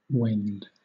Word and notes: wend: British English

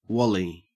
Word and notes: wally: Australian English

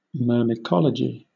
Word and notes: myrmecology: British English

